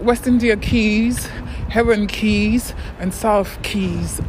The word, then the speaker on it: Quay: The way it's spelled, like Q-U-A-Y. I used to say Quai. Like, people be like, what the fuck is that, Quai? But I say things how it's spelled, all right? So, Quai, but, or Quay. Yeah, Quai or Quay. West Indian Quay, but I know it's Keys. It's like, Keys is K-E-Y-S, Keys.